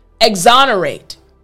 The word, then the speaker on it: exonerate: So right when I get to the O-N portion of the word, exonerate. Even my mouth lifts up just a bit to make sure I'm saying it properly. exonerate, and then it goes back down.